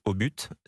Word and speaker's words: Obut: Et j'ai une anecdote là-dessus, puisque à un moment donné, la prononciation de la marque La Boule Obut, c'était plutôt Obut. Il n'y avait pas la prononciation du T. Et c'est à Europe 1, avec Pierre Belmar, lors d'une annonce publicitaire, qu'il a nommé la boule au but, O-B-U-T.